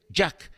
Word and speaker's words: JAC: Jack se escribe J-A-C. J-A-C. Jack.